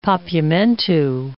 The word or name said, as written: Papiamentu